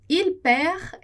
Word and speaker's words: il perd: Lembra lá que o D, o S, o T a gente não pronuncia no final de palavra. Então, isso vai acontecer também com il perd. Il perd. A gente não vai pronunciar o D.